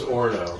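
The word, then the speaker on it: Orono: Orano? Is that how you say it? – I thought it was Orno.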